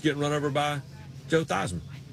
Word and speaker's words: Theismann: The way you pronounce his name is Theismann, but they wanted to do a Heisman campaign for him at Notre Dame, and he became Theismann.